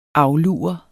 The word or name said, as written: aflure